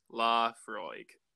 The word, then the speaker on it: Laphroaig: Yeah, another tricky Scotch name from Islay is Laphroaig, pronounced Laphroaig.